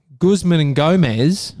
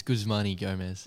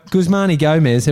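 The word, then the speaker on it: Guzman y Gomez: Guzman and Gomez have released a new burrito. – is that how you say it? – I think it's Guzmani Gomez.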